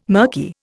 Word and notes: murky: Indian

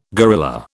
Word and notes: gorilla: British English

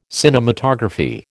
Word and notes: cinematography: American English

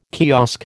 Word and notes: kiosk: American English

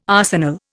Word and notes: arsenal: Indian

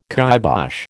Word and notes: kibosh: American English

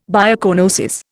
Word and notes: biocoenosis: Indian

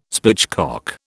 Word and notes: spitchcock: British English

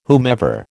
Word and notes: whomever: American English

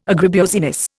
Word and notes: agribusiness: Indian

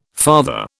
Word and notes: farther: British English

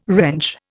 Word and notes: wrench: Indian

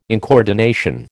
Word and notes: incoordination: American English

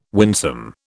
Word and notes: winsome: British English

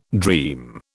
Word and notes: dream: British English

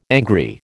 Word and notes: angry: American English

